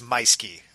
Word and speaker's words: Meuschke: And this is Jason Meuschke, and his name is spelled funny, but it's actually easier to say. And so we'll make sure when we put it in the show notes, it's Meuschke, okay, everybody?